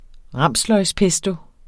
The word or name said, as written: ramsløgspesto